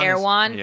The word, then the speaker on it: Erewhon: I don't even know how to pronounce it. Erewhon. – Erewhon. Yeah. Erewhon. Yeah. It's nowhere but backwards.